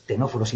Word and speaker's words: ctenóforos: Estos ctenóforos y nidarios, los dos que empiezan por una C que no se pronuncia, ctenóforos y nidarios, son en los que aparece la neurona.